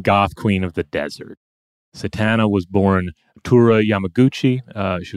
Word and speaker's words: Satana: Well, let's talk about Tura Satana. Not Santana, as I sometimes keep mispronouncing it.